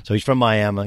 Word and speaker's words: Miami: Miami, it's spelled like Miami, but Miami, Oklahoma.